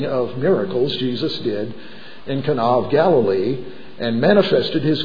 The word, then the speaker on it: Cana: Cana, as most of us would pronounce it, but it's actually Cana in Greek.